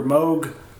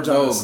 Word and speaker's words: Moog: Is it Moog or Moog? Does anybody know with more knowledge? – I've heard Moog before, so... – I've heard both is the problem.